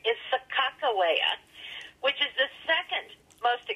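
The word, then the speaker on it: Sacagawea: Now, the captains wrote her name a minimum of 20 times in the journals, and every single time it was broken into syllables because they spelled phonetically, and it was Sacajawea with a hard G sound.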